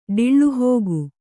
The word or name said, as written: ಡಿಳ್ಳುಹೋಗು